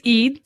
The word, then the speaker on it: Eid: But it's just, and today's Eid.